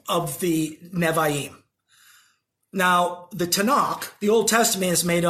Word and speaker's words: Tanakh: That's why you get the pronunciation Tanakh. Because it's the Torah, Nevi'im, and Ketuvim.